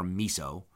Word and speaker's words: meso: Okay, so those, if you hear people say a mesopredator or a mesopredator, depends on how you want to pronounce it, M-E-S-O predator, meso or miso.